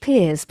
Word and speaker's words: Pears: I need to be clear that it's spelt Pears and pronounced Pears because it's French.